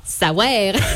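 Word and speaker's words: saouère: Alors pas savoir, savoir. – Comment ça s'écrit? – S-A-O-U. E-R-E.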